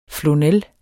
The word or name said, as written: flonel